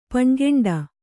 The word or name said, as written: ಪಣ್ಗೆಂಡ